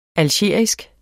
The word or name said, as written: algerisk